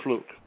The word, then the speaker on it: Fluke: And the worst part is her last name is spelled Fluke, but it's pronounced Fluck. That's Fluke. – Exactly. Yeah, it's Fluke.